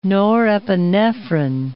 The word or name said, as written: norepinephrine